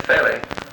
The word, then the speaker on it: felly: I thought a felly... What you split wood with? – I don't know that term at all. – Felly. A felly, that's what you pronounce.